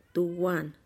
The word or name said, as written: tuwan